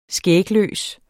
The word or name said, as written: skægløs